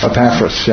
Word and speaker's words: Epaphras: As you also learned from, and how do you say his name? Epaphras? – Epaphras, yeah, good.